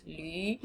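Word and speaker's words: lǚ: It's a funny one to pronounce, lü. So you write it in pinyin with, so it's L, and then if you're typing it, you can type it with a V instead of a U, because L, U, and L, lü, which is a U with like an umlaut over the top, they're two different pronunciations.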